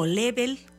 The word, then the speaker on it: Laval: Y pues estamos también pues empezando a calentar los motores para ir a este conciertazo que nos trae el maestro Martin Laval, o Level, como se pronuncia en francés, estos sonidos del Rin.